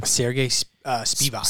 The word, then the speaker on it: Sergey Spivak: Sergey Spivak. – Spivak, right.